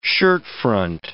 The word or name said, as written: shirtfront